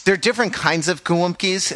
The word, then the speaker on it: gołąbki: We pronounce it as Pichanika Wumpki. Repeat after me. Pieczone. – Pieczone. – Pieczone. – Gołąbki.